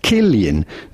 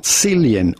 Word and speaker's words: Cillian: Yes, it is to be pronounced Cillian, not Cillian or anything else. Rather, Cillian. Cillian Murphy.